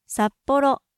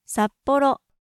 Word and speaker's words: 札幌: I've never been to Sapporo, pronounced Sapporo. Sapporo.